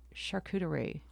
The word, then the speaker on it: charcuterie: Let's talk about charcuterie. – Okay. – Am I saying that right? – You are.